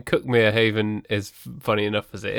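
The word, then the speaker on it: Cuckmere Haven: And also nearby is the lovely village of Cookmere Haven, which is written Cookmere Haven, of course.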